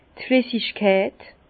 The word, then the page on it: d' Flissigkeit: Bas-Rhin; Herrlisheim